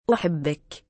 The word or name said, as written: uhibbik